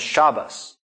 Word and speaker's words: Shabbos: what would be like a tav or a T sound on the end of a word gets turned into an S sound at the end of a word. So that's Shabbat becomes Shabbos.